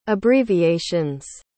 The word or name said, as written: abbreviations